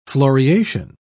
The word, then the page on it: floriation: British English